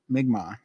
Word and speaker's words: Mi'kmaq: He explains that finding the, how do you pronounce that? – Mi'kmaq. – Mi'kmaq, sorry. I was just want to say Mi'kmaq.